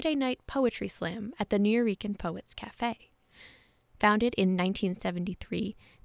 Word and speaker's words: Nuyorican: NUYORICAN.